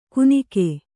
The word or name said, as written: ಕುನಿಕೆ